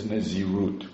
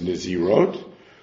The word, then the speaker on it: Nazirot: Some read this as Nazirut, which I don't think is correct. Because Nazirut is also one Nazirut. Well, Sidi Gemara uses it that way. I would suggest the correct pronunciation is Nazirot, or to take the Malachet Shlomo's Girsa, which is Naziriot, which is the way usually multiple Nazirot are referred to.